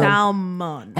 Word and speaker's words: salmon: Salmon. – Hold on, but why the L shouldn't even be there?